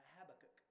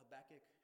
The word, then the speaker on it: Habakkuk: H-A-B stands for Habakkuk or Habakkuk. You can argue all you want to over how you say it. I don't really care. I just call it Habakkuk, Habakkuk.